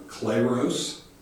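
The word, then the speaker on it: kleros (κλῆρος): Then when I got to looking at the word clergy, it comes from this word below there, kleros, is how you pronounce that.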